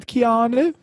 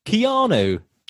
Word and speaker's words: Keanu: Keanu. – Keanu.